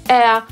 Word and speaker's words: heir: I to słowo czytamy tak, jakby nie było w nim litery H. A więc wymawiamy je identycznie jak angielskie słowo powietrze. Po brytyjsku air, po amerykańsku air.